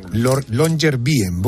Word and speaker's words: Longyearbyen: Longyearbyen.